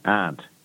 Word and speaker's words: Arndt: Your name, surname is spelled A-R-D-N-T. – No, it's A-R-N-D-T, aunt. But you say it like Aunt Mary. Aunt, yeah. – A-R-N-D-T. – T. It's German from way back.